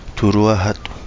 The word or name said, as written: TuluaaHadu